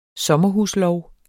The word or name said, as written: sommerhuslov